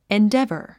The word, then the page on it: endeavor: indévə r; エンデェバァ